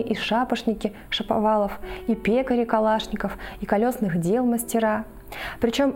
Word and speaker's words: Калашников: Соответственно, мы произносим ее как Калашников.